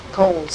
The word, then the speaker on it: Cowles: Was it Cowles or Coles? – Coles. C-O-W-L-E-S. Mm-hmm. At least that's the way she pronounced it.